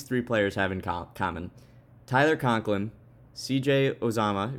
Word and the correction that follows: Uzomah: Uzomah, that's how you say it, right?